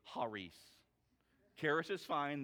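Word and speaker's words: Haris (χάρις): We pronounce it Karis. That is not actually the way that you pronounce it, K-A-R-I-S. When it's talking about this right here, this gracious gift, it's talking about Karis. And the actual pronouncement is Haris, is how you say it.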